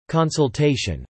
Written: consultation

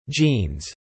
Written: genes

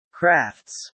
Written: crafts

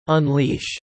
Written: unleash

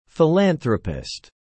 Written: philanthropist

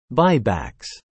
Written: buybacks

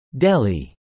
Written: Delhi

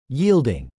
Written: yielding

